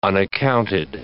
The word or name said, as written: unaccounted